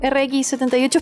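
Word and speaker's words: RX-78: Por ejemplo, a mí no me llamaba para nada la atención armar eh, pero tengo ahí un RX78 esperándome algún día para que lo arme. lo dije bien. Sí, lo dijo bien.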